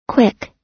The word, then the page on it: quick: American English